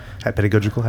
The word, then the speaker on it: pedagogical: Like, what do you feel like you've added to that pedagogical... Pedagogical? How do you pronounce that? – Yeah. – Process. – That would be correct.